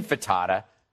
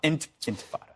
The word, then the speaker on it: intifada: Am I pronouncing that correctly? – Yeah, not infatada. – Right. Not enchilada. Infatada. – Intifada.